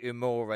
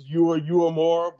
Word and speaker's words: Uemura: Who is Yui Uemura, and did I really just murder that name? – Yes, you are butchering it. It's Uemura, but, you know, I'll give you a break. It's not bad.